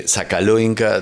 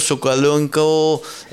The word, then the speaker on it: Sokolonko: Como vieron, Sokolonko, que es como se escribe o como se castellaniza, Sokolonka, dijo ella, algo dijo así. Ella. Sí, y después claro. Cuando canta es algo Sokolonko, no queda tan claro si es Sokolonka o Sokolonko o algo así.